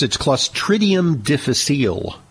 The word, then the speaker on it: Clostridium difficile: It's Clostridium difficile.